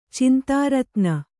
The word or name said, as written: ಚಿಂತಾರತ್ನ